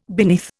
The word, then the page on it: beneath: Indian